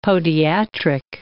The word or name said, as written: podiatric